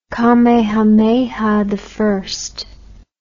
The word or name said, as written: kamehameha i